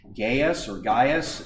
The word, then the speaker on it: Gaius: Gaius, it's pronounced both ways in English, was a very common name.